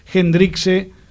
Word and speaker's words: Hendrickse: La primera pregunta es, ¿cómo se dice tu apellido? Porque te hemos dicho Hendrix, Hendrixe. – Valen todas. Hendrix también. – Bueno, como noches, Jimmy, digamos. – Como Jimmy, con una E al final, pero bueno, se puede castellanizar, no hay problema, como saquespear.